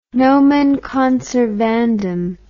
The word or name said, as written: nomen conservandum